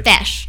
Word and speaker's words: Feis: FESH. FESH, so like T H? – It's spelled F E I S. – F E I S. – Okay. But it's pronounced fesh.